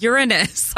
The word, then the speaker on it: Uranus: You guys, it's pronounced Uranus, okay? – Which is almost worse.